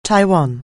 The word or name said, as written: Taiwan